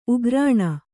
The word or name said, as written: ಉಗ್ರಾಣ